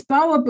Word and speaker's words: Mbawa: No, it's it's Bawa Books, but it's spelled M B A W A dot co dot uk or dot com.